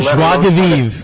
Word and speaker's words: joie de vivre: Joie de vivre.